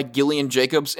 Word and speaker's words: Gillian Jacobs: So the episode Higher, Further, Faster, directed by Gillian Jacobs, and I know I'm saying that right because I saw a Jimmy Kimmel Live episode a while back where she actually pronounced her name, saying that nobody knew how to pronounce that name.